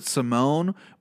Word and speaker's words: Simon: He was kicking his legs, and I think after the bell rang, Ricky Simon, or excuse me, they pronounce it Simone, but if your name is Ricky Simon and you want me to pronounce it Simone, go fuck yourself, because I'm not doing it. Your last name's Simon.